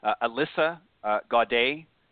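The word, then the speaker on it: Elisa Gaudet: Alyssa Gaudet is how it's pronounced, and it's E-L-I-S-A and G-A-U-D-E-T.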